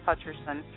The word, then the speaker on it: Hutcherson: My name is Elder Pamela, P-A-M-A-L-A. Last name is Hutcherson, H-U-T-C-H-E-R-S-O-N.